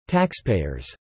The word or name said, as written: taxpayers